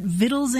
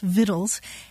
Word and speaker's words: victuals: And so they put the letter C back into it and started spelling it V-I-C-T-U-A-L. But the thing is that the old pronunciation was already so well established that people kept pronouncing it victuals anyway. So today you'll see either the word victual spelled V-I-T-T-L-E or V-I-T-T-L-E-S, victuals, and you'll also see it spelled V-I-C-T-U-A-L-S, victuals. Mm-hmm. And you look it up in the dictionary and the pronunciation is victuals for both words.